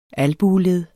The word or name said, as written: albueled